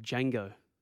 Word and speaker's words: Django: It's like Django. The D is silent.